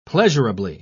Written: pleasurably

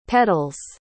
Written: pedals

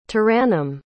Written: tarannum

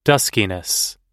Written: duskiness

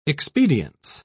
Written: expedience

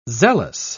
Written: zealous

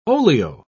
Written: olio

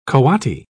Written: coati

